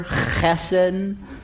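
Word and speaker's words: chesed (חֶסֶד): Loving kindness is a Hebrew term, chesed. If you really want to say it right, you're going to spit on your neighbor, chesed.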